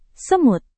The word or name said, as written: semut